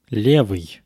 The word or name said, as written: levyy